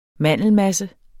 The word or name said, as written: mandelmasse